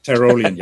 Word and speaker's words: Tyrolean: From what I understand, this is from a lineage, and I don't even know if I'm pronouncing correctly, but Tyrolean? – Tyrolean, yeah.